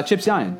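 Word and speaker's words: Chip Zien: Oh, Chip Zion.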